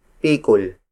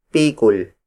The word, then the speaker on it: pikul: And the heaviest unit among native Tagalog measurements is pikul. Pikul. Pikul. Pikul is spelled as P-I-K-U-L.